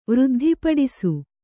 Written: ವೃದ್ಧಿಪಡಿಸು

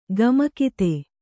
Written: ಕಾಮುಕತೆ

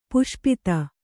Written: ಪಾಶುಪತ

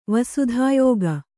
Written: ವಸುಧಾಯೋಗ

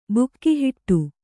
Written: ಬಿಗಿಹಿಡಿ

